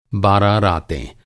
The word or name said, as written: बारह